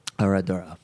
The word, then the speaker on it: Herradura: Herradura.